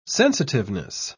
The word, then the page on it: sensitiveness: British English